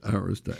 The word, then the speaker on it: Arista: We all, in New York, we all know how to pronounce it. – Yes. – Because it's a name of an honor society that every high school has. – Right. – It's called Arista.